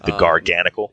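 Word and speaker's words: Garganacl: I had the Rock Salt Pokemon, the Garganical. – Yeah, is that supposed to be pronounced like Knackly or something? I don't know. That it's like NaCl.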